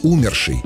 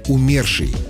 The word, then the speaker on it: умерший: Произносят его с ударением на первый слог «умерший». Увы, хоть этот вариант часто используется в разговорной речи, но нормам русского литературного языка не соответствует. Нормативное произношение «умерший», «умершая», «умершие» с ударением на второй слог.